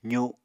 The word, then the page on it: zu: apatani